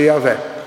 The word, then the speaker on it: Yahweh: Che si legge, sarebbe quelle lettere che ho scritto lì, YHWH, si legge Yahweh,